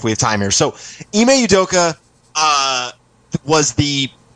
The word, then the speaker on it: Ime Udoka: How, do, how do you say his name officially? Ime Udoka. – You just spell like it I M E and it's it's pronounced Ime? – Yeah.